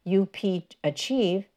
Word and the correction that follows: UPchieve: I'm sorry, I think that's pronounced UPchieve. UPchieve